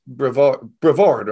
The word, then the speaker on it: Brevard: Let's say that Brevard, right? That's how you pronounce it.